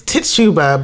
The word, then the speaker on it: Tituba: Tituba. – Tituba? – It's Tituba, but the way it's spelled, I can't like say that.